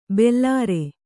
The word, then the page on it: ಭಲರೆ: bhalare